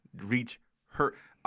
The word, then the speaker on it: Reacher: Reach her. Reach, the word reach, dash her.